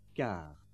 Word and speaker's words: gare: Remember that consonants at the end of words are not usually pronounced. For example, you don't hear the S at the end of heure, nor the T at the end of gare.